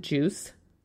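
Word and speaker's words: Juiz: But we're going to pronounce it Juice, Social Post Share. It is spelled, I don't know why, I see Juize when I see it. It's J-U-I-Z, Social Post Share.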